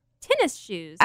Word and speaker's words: tennis shoes: It's one word, tinny shoes. – You said tinny shoes? – Yeah. Oh, is that not how you say it, tinny shoes? – No. Tennis shoes.